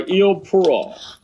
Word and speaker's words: Eau Pure: 这个咱们发音发不准啊，叫 Eau Pure。